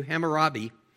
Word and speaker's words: Hammurabi: How do you say that? Hammurabi? Thank you. Hammurabi.